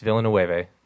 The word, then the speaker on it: Villeneuve: Art House Asshole this week is on Incendies, which is, Denis Villeneuve's last foreign film. – Okay, wait. Say that name again. – Nope. It's Villeneuve.